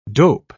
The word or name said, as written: dope